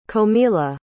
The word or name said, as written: comilla